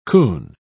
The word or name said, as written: coon